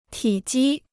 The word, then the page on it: 体积: tǐ jī